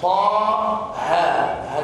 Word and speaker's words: طه: وطه هذان حرفان من الحروف المقطعة، يعني ينطقان هكذا طا ها هكذا.